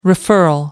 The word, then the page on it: referral: American English